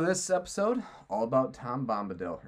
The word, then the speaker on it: Bombadil: But I'll never forget that his name's Bombadil, because that's how it's spelled.